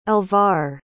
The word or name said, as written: alvar